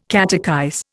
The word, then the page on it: catechize: Indian